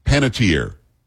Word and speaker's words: Panettiere: The first one that I can remember, a famous person who passed, was it Hayden, is it Pantier? Because I think some said Pantieri, but I think she said Pantier, didn't she? Do you know how she pronounced her name? – I usually say Hayden Panettiere. – Hayden, what did I say? Hayden Panettiere. – I say Panettiere, yeah. – Panettiere. It's an Italian name. It'd be Panettiere.